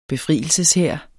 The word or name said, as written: befrielseshær